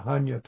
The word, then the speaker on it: Hanya: Prajna Tara, Hanya Tara. Prajna, Sanskrit, Hanya is, I think that's the Chinese Japanese way of, the Sino Japanese way of pronouncing that word.